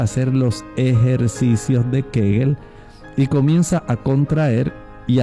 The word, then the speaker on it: Kegel: Kegel, se escribe K-E-G-E-L. Kegel. O Kegel, es como se pronuncia.